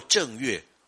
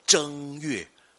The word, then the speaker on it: 正月: 可是呢，你念作正月也是不对的，要念作一声，念作正月。